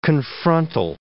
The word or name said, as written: confrontal